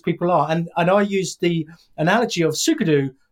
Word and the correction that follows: Sudoku: Sudoku. Did I say that right? I don't know. – And, Sudoku. Yeah, no, that's 100%.